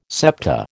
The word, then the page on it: septa: American English